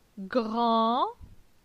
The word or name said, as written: Gran